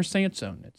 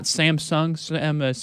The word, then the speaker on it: Sansone: My full name, Kramer Sansone. It's S-A-N-S-O-N-E. Not Samsung, Sanson, or however you want to pronounce it as well. No, that's not what it sounds like, or it's not what it looks like on the wording as well. So get my name right when you do spell it, S-A-N-S-O-N-E.